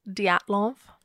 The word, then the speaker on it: Dyatlov: Dial of Pass incident, which was, I think, 60 years ago. So that's pretty crazy. – Actually, it's Dyatlov.